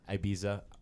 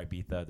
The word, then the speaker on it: Ibiza: For everybody who's wondering out there, Ibiza or Ibiza, depending on how you pronounce it, is in Spain.